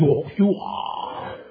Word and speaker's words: Yahshua: That name, Yahshua, is salvation. No other name. That's how you pronounce it. Yahshua. To the Hebrew tongue.